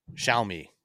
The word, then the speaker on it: Xiaomi: just so everyone knows, it's Xiaomi. I'm like Xiaomi. And how do you say it? – Xiaomi.